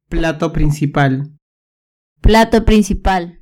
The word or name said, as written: plato principal